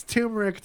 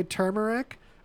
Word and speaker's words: turmeric: Do you say turmeric? Or do you say turmeric? Because apparently, although it's spelled turmeric, both pronunciations are correct.